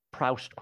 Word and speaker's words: Proust: I'm assuming Proust is correct. – You're assuming she's correct and I'm wrong. – You say it in the proper French pronunciation.